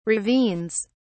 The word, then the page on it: ravines: British English